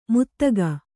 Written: ಮುತ್ತಗ

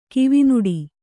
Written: ಕಿವಿನುಡಿ

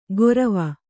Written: ಗೊರವ

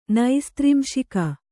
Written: ನೈಸ್ತ್ರಿಂಶಿಕ

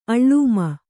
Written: ಅಳ್ಳೂಮ

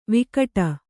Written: ವಿಕಟ

